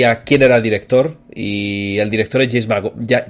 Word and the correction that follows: Mangold: Eh, eh Mangold. no, me equivoco, es el director Mangold. Hombre y oro, todo junto. – Mangold.